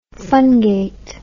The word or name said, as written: fungate